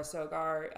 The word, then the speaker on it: Sogar: It's just my name, Laura Sogar, which is spelled LauraSogar. Sugar with an O.